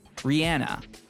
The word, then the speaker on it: Rihanna: We're on a plane on a runway at LAX, Rihanna. And yeah, that is how you say her name.